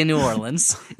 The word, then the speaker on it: New Orleans: which is how you say New Orleans, if you're in New Orleans.